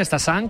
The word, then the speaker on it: Stassin: A pronunciarlo bien, Stassin, como nos han apuntado, y Félix Correia, como dice José, con muy buena pronunciación portuguesa.